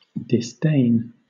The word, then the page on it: disdain: British English